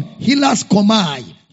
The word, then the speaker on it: hilaskomai: Hilasmos is taken from Hilaskomai.